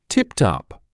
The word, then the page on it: tipped up: [tɪpt ʌp]; [типт ап]